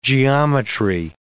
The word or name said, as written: geometry